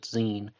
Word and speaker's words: Xen: He spelled it Xen, but he pronounced it Zine, and this guy needs some more thorough vetting.